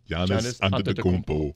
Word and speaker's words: Giannis Antetokounmpo: Giannis, Giannis Antetokounmpo.